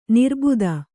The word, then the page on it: ನಿರಾಬಾಧೆ: kannada